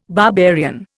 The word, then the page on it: barbarian: Indian